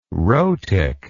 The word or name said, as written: rhotic